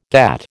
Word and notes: GATT: American English

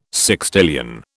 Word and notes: sextillion: British English

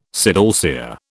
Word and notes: sidalcea: British English

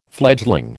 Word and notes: fledgling: American English

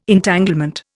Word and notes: Entanglement: Indian

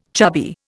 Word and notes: chubby: Indian

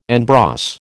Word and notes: en brosse: American English

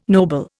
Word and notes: noble: Indian

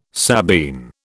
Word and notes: Sabine: British English

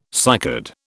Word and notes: cycad: British English

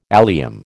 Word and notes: allium: American English

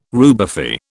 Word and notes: rubefy: British English

